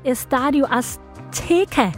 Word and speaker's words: Estadio Azteca: 21, og det er altså på det nyrenoverede, nu håber jeg, at jeg udtaler det nogenlunde rigtigt, Estadio Azteca i Mexico City.